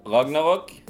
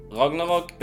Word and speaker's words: Ragnarok: The movie is called Thor Ragnarok. Ragnarok basically translated as Doomsday in Norse mythology,